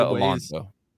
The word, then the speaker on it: Alonso: Victoria Alonso.